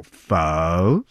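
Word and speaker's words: phở: It's tonic, right? So it's pronounced like a E at the end, but like you're going up and down. So pho.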